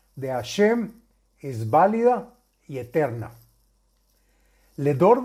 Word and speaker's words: Hashem: Y se deberá meditar el salto nombre Hei Shin Mem, que se pronuncia Hashem.